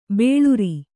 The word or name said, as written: ಬೋಳರೆ